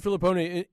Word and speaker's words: Filippone: Is it Filippone or Filippone? Tell me how to say it. – Filippone.